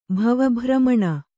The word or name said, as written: ಭವಭ್ರಮಣ